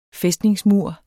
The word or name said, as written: fæstningsmur